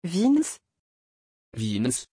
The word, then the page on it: vince: French